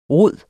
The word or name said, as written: rodet